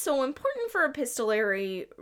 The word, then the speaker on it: epistolary: Epis- epistolary.